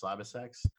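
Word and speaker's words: CyberCX: That's pronounced Cyber Sex.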